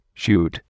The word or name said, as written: shoot